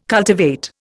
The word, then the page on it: cultivate: Indian